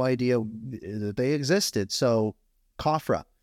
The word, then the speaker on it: COFRA: So COFRA is I think you said it right. It's COFRA. – Okay, good.